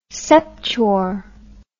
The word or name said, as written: septuor